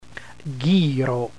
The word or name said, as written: ghiro